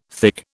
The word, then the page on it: thick: British English